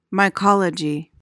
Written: mycology

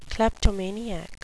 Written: kleptomaniac

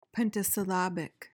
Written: pentasyllabic

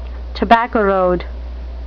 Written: tobacco road

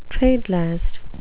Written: trade-last

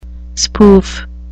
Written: spoof

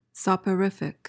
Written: soporific

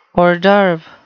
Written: hors d'oeuvre

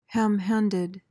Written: ham-handed

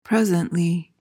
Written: presently

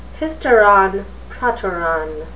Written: hysteron proteron

